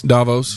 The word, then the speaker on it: Davos: The World Health Organization, who met a week before the 2,700 power brokers descended upon, how do we say that again? – It's Davos.